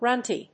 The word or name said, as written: runty